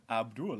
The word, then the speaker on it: Abdul: And when I asked him how to pronounce it, he said Abdul, like Paula.